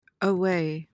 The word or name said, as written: aweigh